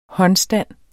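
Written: HÅNDSTAND

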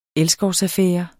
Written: elskovsaffære

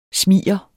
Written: smiger

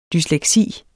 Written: dysleksi

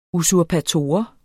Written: usurpator

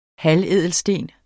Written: halvædelsten

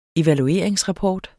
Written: evalueringsrapport

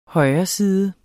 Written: højreside

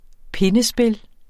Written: pindespil

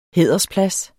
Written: hædersplads

